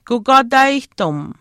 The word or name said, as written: kukwaataayihtim